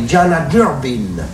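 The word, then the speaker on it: Deanna Durbin: Non, on dit pas Diana Durbin, on dit Diana Durbin.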